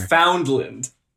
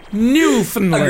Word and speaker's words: Newfoundland: Foundland. – Okay, that's not how you say it, but sure. Yeah, Finland. Newfoundland. – Agree to disagree. – Newfoundland. Yeah, okay.